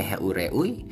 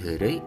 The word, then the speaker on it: heureuy: mungkin ada yang bacanya heureui baca bukan heure tapi hure apa itu hure hure itu adalah bercanda